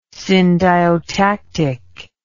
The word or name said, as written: syndiotactic